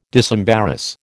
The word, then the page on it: disembarrass: American English